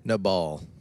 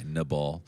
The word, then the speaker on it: Nabal: Nabal. Did I say that correctly? – Nabal. – Okay, Nabal.